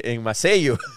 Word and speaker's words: Maceió: Maceio. Maceió e eles, não e eles falavam, repetiam, e, e eu nem sabia que eu estava falando errado.